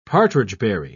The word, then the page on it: partridgeberry: British English